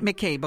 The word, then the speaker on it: McCabe: C'est la famille McCabe, c'est comme ça que vous prononcez ouais. – McCabe, OK?